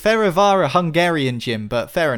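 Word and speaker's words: Fehérvár: Fervar. F-E-H-E-R-V-A-R.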